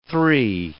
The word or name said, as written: three